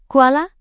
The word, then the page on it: kuala: indonesian